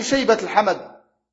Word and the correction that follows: Shaybat al-Hamd: Shaybat al-Hamd.